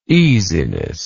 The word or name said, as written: easiness